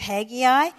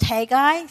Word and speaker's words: Haggai: This morning, we're beginning a three-part series on the book of Haggai. It could be pronounced Haggai, so when you listen to people talk about it, they use both.